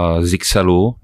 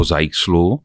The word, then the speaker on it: Zyxel: Není přesně definováno, jestli vyslovovat Zaixl nebo Zixel, Eh, je to v podstatě na každém, jak, eh, jak lidově si říká, leze mu to z pusy. A v čínštině eh, je to tuším zajcel, v angličtině je to zase zajzl, takže ty výklady jsou úplně různé.